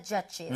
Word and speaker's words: judge: 그런데 이거 발음을 할 때요, D 사운드를 약하게 해서요, judge. 그리고 마지막에 입모양이 하고 모여야 됩니다.